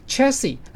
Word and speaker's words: chassis: 电动车我常常听广播里面讲到说，哎，有了自驾车，电动车最重要的就是它的底盘 chassis、底盘最后的 s 不发音耶，好酷啊！